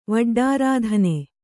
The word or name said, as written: ವಡ್ಡಾರಾಧನೆ